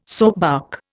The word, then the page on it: soapbark: Indian